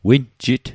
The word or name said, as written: winjit